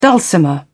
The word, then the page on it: dulcimer: British English